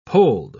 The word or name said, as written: polled